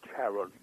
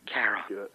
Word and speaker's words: Charon: I tend to go with Charon. – Charon.